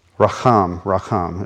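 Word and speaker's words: Racham (רחם): I want to make sure I'm saying it right. And thankfully I have the e-sword in front of me. Here we go. Make sure I'm pronouncing it correctly. Racham. It was close.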